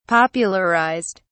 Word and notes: popularized: British English

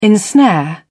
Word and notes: ensnare: British English

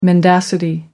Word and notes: mendacity: American English

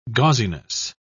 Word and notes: gauziness: British English

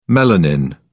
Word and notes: melanin: British English

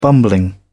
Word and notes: bumbling: British English

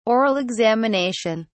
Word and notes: oral examination: British English

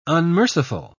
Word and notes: unmerciful: British English

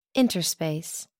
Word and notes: interspace: American English

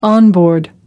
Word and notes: on board: American English

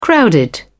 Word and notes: crowded: British English

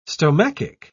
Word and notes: stomachic: British English

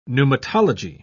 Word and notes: pneumatology: British English

